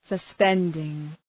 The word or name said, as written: suspending